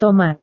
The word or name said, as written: tomat